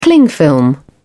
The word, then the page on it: cling film: British English